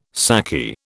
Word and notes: saki: British English

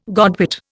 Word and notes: godwit: Indian

